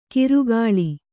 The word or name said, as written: ಕಿರುಗಾಳಿ